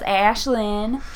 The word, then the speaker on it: Ashleighlynn: My name is Ashleighlynn Jackson,